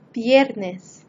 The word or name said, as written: viernes